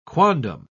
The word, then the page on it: quondam: British English